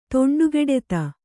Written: ತೊಂಡುಗೆಡೆತ

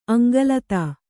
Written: ಅಂಗಲತಾ

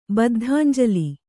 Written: ಬದ್ಧಾಂಜಲಿ

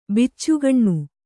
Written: ಬಚ್ಚಗಾನಿ

